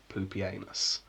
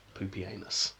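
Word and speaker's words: Pupienus: You can't even get round it saying Poopy Anus, which lots of people say with the usual anus end of the names, because it's actually spout E-N-U-S, but the pronunciation is Poopy Anus.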